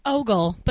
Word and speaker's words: Ogle: Ogle. But Ogle. That's okay. Who would know how to pronounce that word name? Hi, I'm Lauren Ogle.